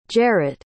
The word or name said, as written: jerett